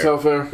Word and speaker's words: Telfair: Sebastian Telfair.